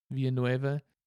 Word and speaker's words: Villanueva: In Spanish, the double L's make like a Y sound. – Yeah. – So it'd be like Villanueva. – Villanueva.